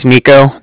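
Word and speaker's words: Niko: And then, like I said, my name is Nico. That's N-I-K-O.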